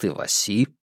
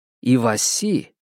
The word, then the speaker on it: Иваси: Иваси, Иваси, что и значит сардина. Одно из редких слов, взятых нами у японцев. В России оно получило французистое ударение на последний слог и даже мужской род, превратившись в Ивася.